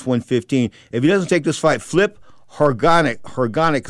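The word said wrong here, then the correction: Hrgović: is going to step in instead. He's supposed Herkovic. – To take how do you say that? – Herkovic. – All right, Herkovic is going to step in.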